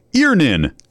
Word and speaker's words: Earnin: E-A-R-N-I-N is pronounced Earnin. I don't know how else you would pronounce it, but they really want it clear that that's how to pronounce it.